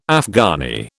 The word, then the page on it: Afghani: British English